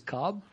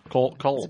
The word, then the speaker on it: Kolb: And not to mention he has a silent L in his name. Why don't they call him Kevin Kolb? – It is Cobb. – Kolb.